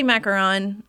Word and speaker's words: macaron: Say it the way you were saying the macaroon. I'm saying it. – I say macaron. Macaroon are like the, the coconut? – Yes.